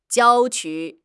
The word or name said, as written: jiao1 qu1